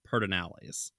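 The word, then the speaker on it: Pedernales: the way everybody pronounces it is Paternalis, like P-E-R-D, uh-huh. And then just sort of slur it all together, Paternalis now.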